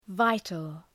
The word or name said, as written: vital